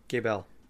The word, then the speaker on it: Gebel: If Gables was French, we would pronounce his name Gebel.